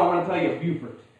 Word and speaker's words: Beaufort: Well, I always say it like this. I'm from a town called Beaufort. It was named after a Frenchman by the name of Beaufort. But it's Beaufort. And if you go there, it's Beaufort.